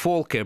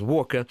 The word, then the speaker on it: Volker: I pronounce him in German way, it would be actually perfect. Volker, but Americans pronounce it as Walker.